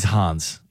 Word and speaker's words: Hans: He's Hans. He's German. – I was trying to rhyme with fan.